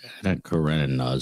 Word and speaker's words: Anna Karenina: Anna Karenina is how I say it, but.